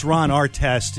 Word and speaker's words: Artest: At the Artist, A-R-T-I-S, not artist, but artist. – Okay. – At the Artist, artist. – I think it's pronounced Ron Artest. – Yeah, that's what it is.